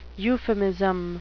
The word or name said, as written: euphemism